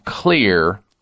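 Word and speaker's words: Xlear: And the way you do that is with a potent drug-free option called Clear Rescue Nasal Spray. Now, the word clear is spelled with an X, X-L-E-A-R.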